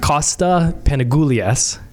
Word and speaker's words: Panagoulias: Okay, so I'm live with Kosta Panagoulias.